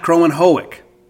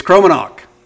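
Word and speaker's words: Kromenhoek: It's Crow, then Men, Hawk. It's not Crow and Hoek. That's how it reads. Phonetically, that's how it looks. But it's Cromanhawk. Croman Hawk. Croman Hawk. Luke Cromanhawk.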